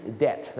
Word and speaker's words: debt: debt นั่นเองครับ in debt ก็จะแปลว่าอะไรครับเป็นหนี้นั่นเอง debt ไม่ใช่ debt นะฮะบางคนออกเสียง debt ไม่ต้องแม้มปากบางคนออกเสียง debt ฝรั่งก็เปียกตาเลย